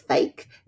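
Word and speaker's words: FAIK: He's got a new one called, I think you pronounce it as fake. It's F-A-I-K.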